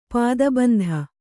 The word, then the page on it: ಪದಬಂಧ: pada bandha